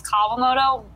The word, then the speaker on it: Kawamoto: And it's close enough. It's to the point now where I don't usually correct people. Technically, it's Kawamoto, but...